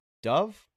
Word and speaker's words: Dov: D-O-V? Dov? His name is Dov?